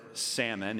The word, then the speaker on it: Salmon: Salmon, or Salmon, if you say it correctly, the father of Boaz, whose mother was Rahab.